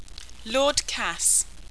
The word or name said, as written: Lord Kass